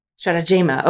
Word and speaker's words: Stratagema: And my final addition to that is around Stratagema. Am I pronouncing that right? – Stratagema. – Okay, thank you.